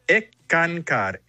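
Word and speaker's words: Eckankar: Y hablábamos de que eres miembro de ENCCAR, ¿no? – Se pronuncia ECANCAR, S-K-A-N-K-A-R, significa ECANCAR.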